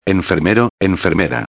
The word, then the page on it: enfermero/a: espanhol